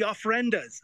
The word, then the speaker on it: ofrendas: Hey, listen, can you tell us a little bit about, and I hope I'm pronouncing this correctly, the Avrundas or altars? – Yeah, that's right, the ofrendas.